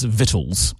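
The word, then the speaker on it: victuals: No, actually, the whole word is silent because it's pronounced vittles. And I said, where the hell does it say vittles? – Like vittles.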